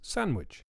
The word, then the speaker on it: sandwich: Another very common word that is mispronounced is sandwich. It also has a missing D.